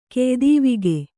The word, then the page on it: ಕೆಯ್ದೀವಿಗೆ: keydīvige